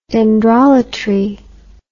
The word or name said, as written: dendrolatry